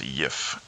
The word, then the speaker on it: GIF: I believe it's pronounced yif. It's a soft G. Yif.